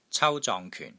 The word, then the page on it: CAU1 ZONG6 KYUN4: Cantonese